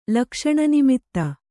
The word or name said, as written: ಲಕ್ಷಣನಿಮಿತ್ತ